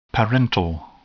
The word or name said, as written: parental